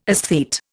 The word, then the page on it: esthete: Indian